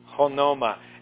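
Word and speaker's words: honoma (ὄνομα): Honoma. I say it like there's a H in front of it because that's what the little apostrophe on the top does to it. It almost turns it into H-O-N-O-M-A, the Greek letters there.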